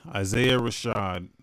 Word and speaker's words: Isaiah Rashad: So you say his name was Isaiah Rashad? – Isaiah Rashad.